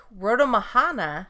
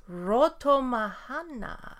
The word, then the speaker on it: Rotomahana: A Weru, or Spirit Canoe, in Lake Rotomahana, New Zealand. – I think you pronounced everything just as it needed to be pronounced. – Rotomahana.